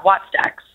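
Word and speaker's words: Wattstax: Yeah, Watts Dax.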